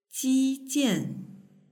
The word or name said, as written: jijian